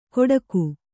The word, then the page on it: ಗೊಡಗು: goḍagu